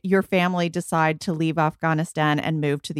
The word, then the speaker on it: Afghanistan: Well, first, I have to say, I love how you say Afghanistan. – I'm trying. – It's really beautiful. It's a very authentic way of saying it, Afghanistan. So I applaud you for knowing how to pronounce that.